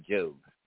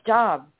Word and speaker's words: Job: It's Job, or Job. She goes, no, it's Job. Anyway, spelled like Job, right? J-O-B? – Anyway, let's talk about Job.